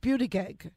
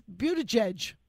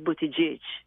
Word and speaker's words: Buttigieg: But it's pronounced Buttigieg, and this poor man thinks his name is Buttigieg. So, what happened there? – In fairness, in Maltese, it's pronounced Buttigieg.